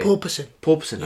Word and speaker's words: porpoising: Did they have purposing? How do you say, by the way? – Porpoising. – Yeah. I keep saying purposing.